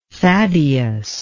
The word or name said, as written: Thaddeus